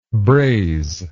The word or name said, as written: brazer